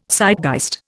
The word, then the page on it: Zeitgeist: Indian